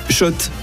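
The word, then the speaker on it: Schott: Mika, tu vas remporter des gants Shot. – Jouer et gagner sur RMC avec Shot, la marque iconique new-yorkaise depuis 1913. Oui, Shot et pas Scott. Ça fait des années qu'on prononce mal la marque.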